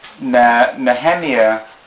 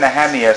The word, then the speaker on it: Nehemiah: I said Nehemiah. Some people say Nehemiah. Yeah. All right. – So thank you. That's better. Much better.